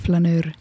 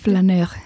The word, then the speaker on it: Flaneur: Flaneur on see õige hääldus. – Eesti keeles vist võiks küll öelda Flaneur. – Flaneur, aga õige prantslane hääldaks seda kindlasti teisiti. – Flaneur.